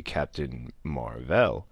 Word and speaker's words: Captain Mar-Vell: It started with Captain Marvel, and for those who have seen it, you now know why it's actually Captain Mar-Vell. And if you haven't, once you do, that pronunciation will make a lot more sense.